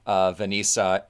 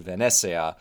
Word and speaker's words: Venezia: The other one was that a handful of very kind commenters from Italy and from Venice in particular pointed out that my pronunciation of Venisa is incorrect, and it's actually much closer to Venezia, which is similar to Venezia, but still not the same, still different.